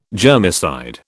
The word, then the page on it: germicide: British English